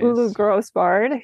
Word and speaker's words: Grosbard: Is it Grosbard? – Yeah, that's how I pronounce it. At least. Ulu Grosbard, who was a theater director and also um, a filmmaker that both worked with.